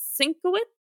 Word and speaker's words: Sienkiewicz: Inker is Bill Sienkiewicz.